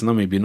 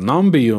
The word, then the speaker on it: Namībiju: nevarēja izrunāt valsts nosaukumu Namībiju. Nu, viņš esot divas reizes Namībiju nosaucis par Nambiju, un, nu, protams, izraisot ļoti daudziem smieklus.